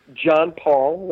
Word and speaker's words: John Paul: It's John Paul, like the Pope.